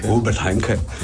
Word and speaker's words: Robert Henke: Robert Henke.